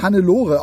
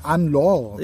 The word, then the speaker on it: Anne-Laure: Sie heißt bestimmt nicht Hannelore, auch wenn es so geschrieben ist. Ich würde mal sagen Anne-Laure.